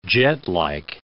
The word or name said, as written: jetlike